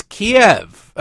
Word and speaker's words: Kiev: I'm not, it's Kiev, okay? It's just like the chicken dish. That's where it comes from, I think. I don't know, I don't care, but that's how it's always been pronounced.